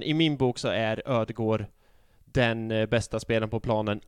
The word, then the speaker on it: Ødegaard: Du just sa det, ingen D. – Just det, Ödegård. Precis, Silent D Ödegård. I slutet.